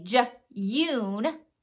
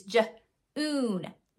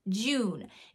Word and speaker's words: June: We can't have them say j-yoon, j-yoon. That's not the way we pronounce that word. It's j-oon, June. It's the oo sound.